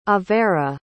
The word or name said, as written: avera